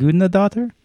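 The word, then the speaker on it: Guðnadóttir: Mm. Gudnadottir? I believe is how you say her name.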